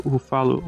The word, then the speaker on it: Ruffalo: Então você fica naquela, tem o policial correndo atrás dele, que é o Mark Ruffalo, ou Mark Ruffalo, sei lá. – Ruffalo. – É isso aí. – É igual o Búfalo.